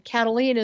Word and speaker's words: Kataline: And all you got to know is spell Karen the normal way, and Cataline is spelled K-A-T-A-L-I-N-E.